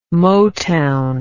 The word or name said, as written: motown